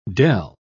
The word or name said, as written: dell